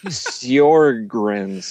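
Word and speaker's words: Sjögren's: Sjorgen's... Oh, I can't even say it right. It's one of those Swedish things. Sjogren's syndrome.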